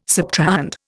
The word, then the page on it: subtrahend: Indian